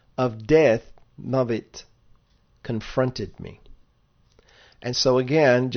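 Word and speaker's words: mavit (מות): Mem vav tav, mavit.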